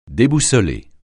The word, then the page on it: déboussoler: French